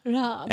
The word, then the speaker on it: Raab: And they worked with a sound designer called Joel Raab. Believe that's how it's pronounced. It's R-A-A-B. – Rob.